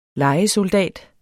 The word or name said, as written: LEJESOLDAT